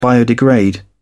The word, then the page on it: biodegrade: British English